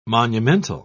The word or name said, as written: monumental